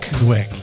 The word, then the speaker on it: Dweck: Dat is werk van, uh, het goed uitspreken, Carol Dweck. – Dweck, yes. Okay.